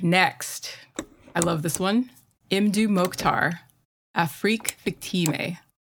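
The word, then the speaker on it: Mdou Moctar: I heard him say his name himself. It is Imdu Mokhtar. – Nice. Okay. Imdu Mokhtar.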